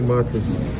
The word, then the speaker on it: Matas: I like Matas. Am I saying his name right? – Yes.